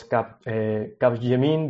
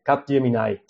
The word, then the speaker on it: Capgemini: I thought that it was Capgemini because you know that it has like a French it had a French pronunciation, but nowadays I think that most of the people call it by Capgemini.